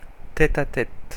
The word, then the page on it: tête-à-tête: /tɛ.t‿a.tɛt/; France (Paris)